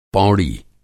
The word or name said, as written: पौड़ी